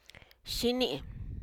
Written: xini

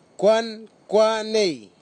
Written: kwankwanei

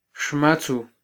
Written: xmatzu

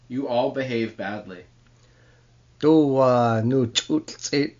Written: duu-waa-nuu-ch’ut’lh-t’sit

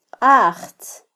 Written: qajtz

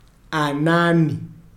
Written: anani

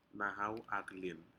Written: nahau aclin

